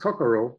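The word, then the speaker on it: kokoro (心): Heart comes from, this is this Japanese tattoo here, which is pronounced kokoro. In Chinese, they say shin, and it means heart or essence.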